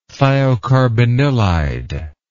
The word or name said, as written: thiocarbanilide